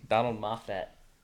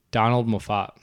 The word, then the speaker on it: Donald Moffat: Donald Moffat. – Donald Moffat.